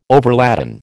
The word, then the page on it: overladen: American English